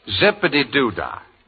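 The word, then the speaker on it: Zip-a-Dee-Doo-Dah: Zippity-doo-dah.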